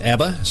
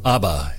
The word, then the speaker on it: Abba: Some people pronounce it Abba. Some people pronounce it Abba. Either way, Abba or Abba Father.